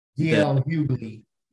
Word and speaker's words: D.L. Hughley: D.L. Hughley? Is that, did I get his name right? – D.L. Hughley, the comedian.